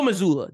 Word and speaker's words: Mazzulla: Joe Mazzulla.